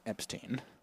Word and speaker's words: Epstein: It's actually pronounced Epstein.